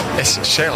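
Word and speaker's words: Chelle: No se dice Shell. – ¿Qué? – Eric Shell. – A mí lo que me decían es cuando vos no sabés la pronunciación. – Es Shell.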